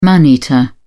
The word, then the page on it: man eater: British English